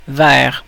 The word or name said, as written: toward